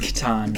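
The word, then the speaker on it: Katan: Kitten. – It's pronounced Catan. – Catan with a K.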